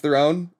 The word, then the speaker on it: Theron: There was Charlize Theron. It's not Charlize Theron. It's Charlize Theron.